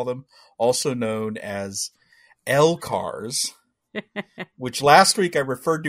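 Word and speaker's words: LCARS: and he said there is no canon pronunciation of L-cars. But he uses L-cars.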